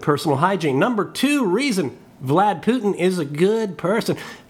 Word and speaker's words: Vlad: His nickname is Vlad. Rhymes with dad and glad.